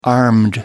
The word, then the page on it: armed: American English